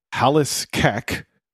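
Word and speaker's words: falbh 's cac: It's pronounced halas cack, although it's spelled F-A-L-B-H apostrophe S-C-A-C.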